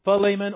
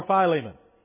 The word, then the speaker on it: Philemon: Philemon or Philemon. All right. Both pronunciations are acceptable. I freely confess I was raised saying Philemon. But Philemon, you will find as an acceptable answer. One is sometimes referred to as the American pronunciation versus the other.